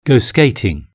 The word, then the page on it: go skating: British English